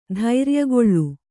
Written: ಧೈರ್ಯಗೊಳ್ಳು

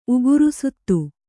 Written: ಉಗುರುಸುತ್ತು

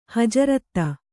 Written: ಹಜರತು

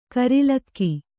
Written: ಕೂರಲಗು

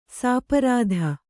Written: ಸ್ಪರ್ಧಿ

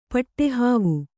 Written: ಪಟ್ಟೆಹಾವು

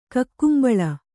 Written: ಕಕ್ಕುಂಬಳ

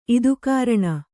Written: ಇದುಕಾರಣ

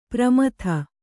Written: ಪ್ರಮದೆ

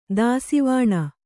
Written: ದಾಸಿವಾಣ

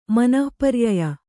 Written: ಮನಃಪರ್ಯಯ

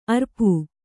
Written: ಅರಿಪೂ

